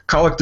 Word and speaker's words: Collect: Collect is a bit of a funny-sounding word, but it is spelled the same way as collect, like you may collect Barbie dolls or Star Wars action figures.